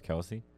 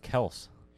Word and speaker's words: Kelce: I saw this interview this morning about Travis Kelce. And he openly said that his name is not pronounced Kelce. I did not know that. It's pronounced Kels.